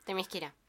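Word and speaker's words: Themyscira: Que conocemos a Temisquera, sí. – ¿se dice así? – Temisquera, sí.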